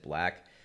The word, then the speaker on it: 6LACK: But number 94 is Problems by, it's spelled 6LACK, but I know it's supposed to be said Black.